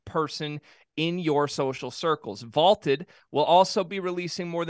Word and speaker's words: Vlted: That's Vaulted, V-L-T-E-D, pronounced Vaulted.